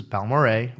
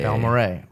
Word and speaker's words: Balmorhea: So this is Balmoray, which I was pronouncing like completely wrong for years, and then I actually was at dinner with Dan Garrison, and he corrected me, and then it was, I think I drank half the bottle that night.